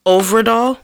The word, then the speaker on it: Øvredal: So uh, director Andre Øvredal. Mm-hmm. Oh, I, I, said it right. – Yay. Yes, done good.